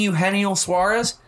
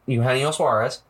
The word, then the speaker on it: Eugenio Suarez: And Eugenio Suarez. Yes, that is how you pronounce that. Eugenio Suarez.